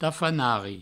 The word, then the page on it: tafanari: provençal